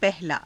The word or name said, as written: pahalaa